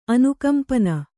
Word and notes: ಅನುಕಂಪನ: Kannada

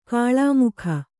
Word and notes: ಕಿಳ್ಮೊಗ: kiḷmoga

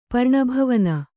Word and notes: ಪರ್ಣಭವನ: Kannada

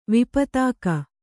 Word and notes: ವಿಪತಾಕ: kannada

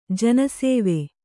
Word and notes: ಜನಸೇವೆ: jana sēve

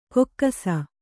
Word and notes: ಕೊಕ್ಕಸ: kokkasa

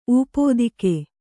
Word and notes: ಊಪೋದಿಕೆ: ūpōdike

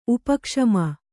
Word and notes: ಉಪಕ್ಷಮ: upa kṣama

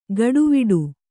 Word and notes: ಗಡುವಿಡು: gaḍuviḍu

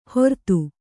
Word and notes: ಹೊರ್ತು: Kannada

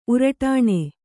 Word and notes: ಉರುಟಾಣಿ: uruṭāṇi

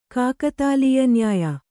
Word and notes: ಕಾಕತಾಲೀಯನ್ಯಾಯ: kākatālīya nyāya

